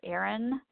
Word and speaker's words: Erin: And my name is spelled Erin, E-R-I-N, Chamerlick, C-H-A-M-E-R-L-I-K.